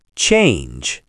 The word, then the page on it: change: American English